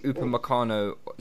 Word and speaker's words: Upamecano: Asked if you had the choice of Upamecano. See, I'm saying it right now, guys. Yeah, I've been practicing. Yeah. – Well done, man. – Upamecano or Zaha and Jan, who would you sign? – Upamecano.